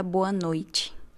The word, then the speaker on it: boa noite: Y por otro lado, decir buenas noches antes de acostarnos, antes de irnos a dormir, que en portugués sería boa noite. que tiene la misma pronunciación que, que bom dia e boa tarde, né? – Boa noite, que tiene la pronunciación de esa T, que parece una Che.